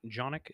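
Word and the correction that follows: Jokić: is that how you pronounce it? – It was a... What? – How do you actually pronounce it? – Jokic. – Jokic. – Yeah, Jokic.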